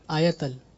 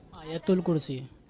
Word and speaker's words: آیت الکرسی: آیتل. – آپ لوگ سب کر رہے ہیں آیتل آیتل، اور صحیح تلفظ کیا ہے؟ – آیت الکرسی.